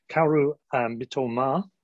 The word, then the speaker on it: Mitoma: Kaoru Mitoma, as it's pronounced apparently,